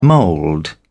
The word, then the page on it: mould: British English